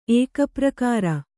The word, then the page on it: ಏಕಪ್ರಕಾರ: kannada